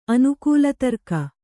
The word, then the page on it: ಅನುಕೂಲತರ್ಕ: anukūlatarka